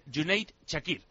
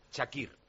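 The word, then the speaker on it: Cüneyt Çakır: dice que la C en turco es como Y y la cedilla como Che, entonces sería Junaid Chakir. – Chakir.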